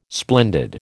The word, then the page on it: splendid: American English